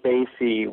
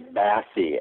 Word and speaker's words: Basie: While I appreciate that the Count Basie would recognize his name, it's surely Bassie, as in the fish.